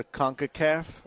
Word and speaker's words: CONCACAF: The CONCACAF.